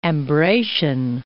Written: Ambracian Gulf

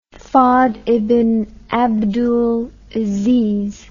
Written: fahd ibn abdul aziz

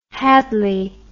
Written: hadlee

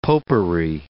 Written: popery

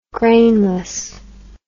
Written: grainless

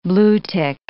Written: bluetick

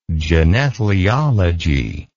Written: genethlialogical

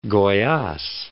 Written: Goyaz

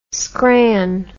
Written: scran

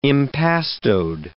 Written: impastoed

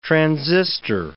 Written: transistor

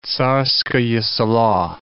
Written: Tsarskoye Selo

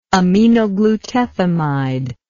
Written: aminoglutethimide

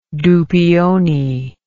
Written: douppioni

